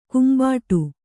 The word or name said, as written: ಕೆಂಬಟ್ಟೆ